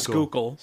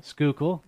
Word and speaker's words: Schuylkill: You got either the Delaware and the Montrose, or you got the Delaware and the Schuylkill? Am I pronouncing it? Schuylkill. – Schuylkill, thank you.